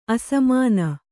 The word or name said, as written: ಅಸಮಾನ